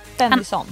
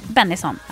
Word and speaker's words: Bennison: Bennison. – Bennison, ja.